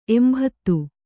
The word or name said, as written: ಎಂಭತ್ತು